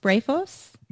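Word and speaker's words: Brephos (βρέφος): And in Luke, you know, John the Baptist leaps in Elizabeth's womb, and Luke refers to him as Beatros, or can you pronounce it for me? Brephos.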